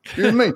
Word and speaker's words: Ewan: Ewan, E-W-A-N. How do you say that? – Ewan. – Ewan Mead, flip-flops with the Jimmy Buffett. – You and me.